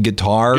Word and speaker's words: GTR: GTR, remember them? You pronounced it guitar. – Guitar, yeah.